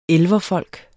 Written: elverfolk

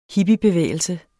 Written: hippiebevægelse

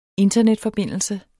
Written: internetforbindelse

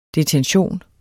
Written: DETENTIONENS

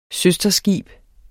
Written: søsterskib

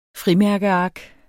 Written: frimærkeark

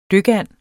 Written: DYKANDEN

